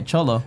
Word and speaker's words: cholo: Outside, there are some gangsters, or as they call them in there, chulos. – No, I'm not saying that right. Cholo.